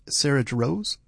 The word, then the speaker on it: Jarosz: We started with Sarah Jarosz. Is there a Z at the end? – There is a Z, but you don't pronounce it.